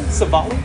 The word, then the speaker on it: Civale: Did I say that correctly? Civale? – That's correct.